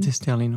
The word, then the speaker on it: Easterlin: Easterlin. Easterlin, ouais.